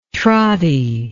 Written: trotty

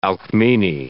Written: Alcmene

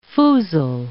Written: foozle

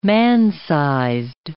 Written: man-sized

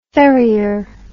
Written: ferrier

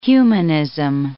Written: humanism

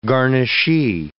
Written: garnishee

